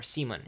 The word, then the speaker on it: Simon: And eventually, when it comes time enough for her to marry, her father, Lavrens, engages her to this guy named Simon, or Seaman, if you are listening to the audiobook. And they translate these incredibly simple English names into, you know, the Norwegian pronunciation.